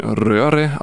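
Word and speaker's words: Røre: Das ist norwegisch, müsste es sein, beziehungsweise, ich habe es extra mal geschaut, ist ein R-O-R-E, heißt die ganze Band oder dieser Musikproduzent. Müsste eigentlich wie ein Röhre ausgesprochen sein, für Rühren und so weiter.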